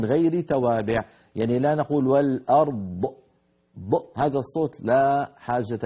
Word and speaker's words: الأرض: يعني ممكن أيضا ننبه على ثقلت في السماوات والأرض وقفنا عليها، والأرض لما نقف عليها يجتمع عند النطق ساكنان، الراء ساكنة والضاد سكنة الوقف، فهذا بحاجة إلى مزيد انتباه أن نبين الراء وأن نبين الضاد. والأرض هكذا نبين الراء لوحدها والضاد